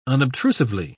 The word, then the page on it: unobtrusively: British English